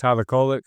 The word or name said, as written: tyler KOLEK